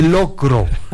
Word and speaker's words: locro: No, usted, ¿por qué dice loco? – No, le estoy diciendo al notario. – Y no se dice loco, se dice locro.